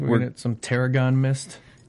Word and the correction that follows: Terrigen: The Terrigen.